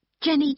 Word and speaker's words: Jenny: No, it's T-H-O-M-S-O-N. – Okay. – Number six. – What name is it? – Jenny Chan. – Can you spell that, please? – Yes. J-E-N-N-Y-C-H-A-N.